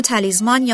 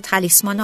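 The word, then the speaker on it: Talisman: خودرویی که برای شما دوستان انتخاب کردیم، رنو تالیزمان، یا حالا تالیسمان، حالا دوستانی سه تلفظ کنید یا زه تلفظ بکنید، اینو انتخاب کردیم که در حقیقت راجبش باهاتون صحبت بکنیم.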